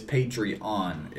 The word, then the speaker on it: Patreon: Emphasis on the E-O-N. It's not Patreon. It's Patreon. And then when you donate, you're called a patron.